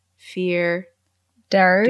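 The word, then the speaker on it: fear dearg: The name Far Darrig is an anglophone pronunciation of the Irish words fear, Derg.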